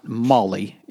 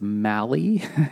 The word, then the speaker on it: Maali: So anyway, he like uh, so Molly is is not Molly, it's Mally, M A A Mal or M A A L I.